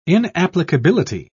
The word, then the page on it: inapplicability: British English